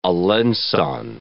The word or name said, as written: alencon